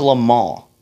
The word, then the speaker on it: Le Mans: going to Le Mans this past weekend. I feel so, like, uppity when I say that, but I'm just pronouncing it in the right French way it's supposed to be produced.